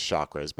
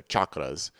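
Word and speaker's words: chakra: Everybody always says chakras, but they're chakras, if you're actually going to use the Sanskrit word.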